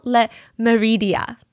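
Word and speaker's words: Le Méridien: It's called Hotel Le Meridien. I'm trying to pronounce it in the French way.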